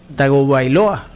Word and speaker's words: Tagovailoa: Tagovailoa. – ¿Lo dije bien? Sí, sí, señor.